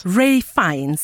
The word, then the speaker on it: Ralph Fiennes: Ralph Fiennes.